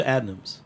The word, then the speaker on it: Adnams: I've brewed with Adams a bunch of times now, a bunch of times more in the UK, and Adams came over to visit Six Point as well. Going over to Adams is fantastic. – Adams or Adnams? – Adnams, A-D.